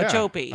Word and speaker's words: Okeechobee: Yeah, it's in Okeechobee. Is that, I think that's how you pronounce it. – Yeah. – Okeechobee. – I think that's correct.